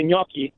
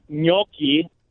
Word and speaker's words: gnocchi: So you say, I often say gnocchi, but you got to say it fast with that syllable, that excess syllable on the second syllable. So when you say gnocchi, but when you say it fast, it's gnocchi. See? – I'm confused.